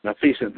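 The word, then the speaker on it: Maleficent: Number 5 was, I'm trying to pronounce this, Malefecent. – Malefecent, thank you.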